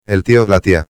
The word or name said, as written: el tío/a